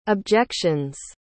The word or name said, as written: objections